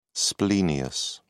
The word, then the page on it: splenius: British English